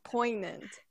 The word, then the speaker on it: poignant: The G is silent. It's actually a weird word. – Poignant.